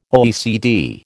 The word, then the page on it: OECD: American English